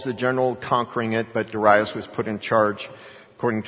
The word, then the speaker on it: Darius: That very night, Belshazzar, king of the Chaldeans, was slain, and Darius, and I usually call him Darius, because when I used to call him Darius, somebody said, no, the proper pronunciation, non-Western, is Darius.